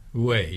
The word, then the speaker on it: Huet: Henri Huet, how do I pronounce that? Huet? – Way, yeah.